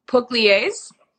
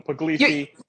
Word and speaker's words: Pugliese: There's no right way to say my name in English because it's an Italian state called Puglia. So okay. My name is Pugliese, but I tell everybody Pugliese.